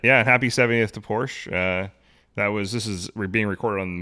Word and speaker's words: Porsche: Make sure you say it right. It's two syllables. – Porsche. Porsche is a two-syllable word.